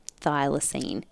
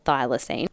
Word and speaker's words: thylacine: But these are just like the facts, right, about the thylacine. Is that how you say it? – I think so. I've always said it that way.